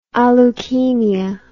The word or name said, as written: aleukemia